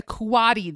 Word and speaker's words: coati: coati. Yes, you heard me right, a coati. That's spelled C-O-A-T-I.